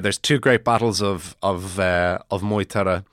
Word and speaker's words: Moytura: So it's Maghturiadh, as far as I know. So I've been pronouncing it Maghturra, but I've seen a few anglicized phonetic spellings of it that have been pronouncing it Moytura. So that's what I'm going to go with for the moment, until I'm contradicted or until I see one that works better.